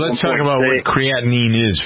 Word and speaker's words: creatinine: Who's interested in figuring out what it means to the creatine, I never heard pronounced that right. – Creatinine levels.